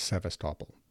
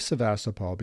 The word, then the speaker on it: Sevastopol: Now let's focus on our main subject, Sevastopol, also known as Sevastopol. Both pronunciations apparently are acceptable. Quote, "The city of Sevastopol began as a small garrison town on the south coast of Akhtiar Bay.